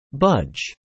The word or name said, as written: budge